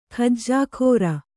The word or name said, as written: ಖಜ್ಜಾಖೋರ